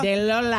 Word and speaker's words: Delola: De Lola. – How do you spell that? – D-E-L-O-L-A.